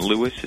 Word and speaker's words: Lewis: That's all one word, and Lewis is spelled L-E-W-I-S.